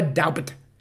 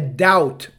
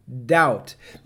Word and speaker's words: doubt: Mas não, tem, não é doubt, é doubt, o B é mudo, doubt.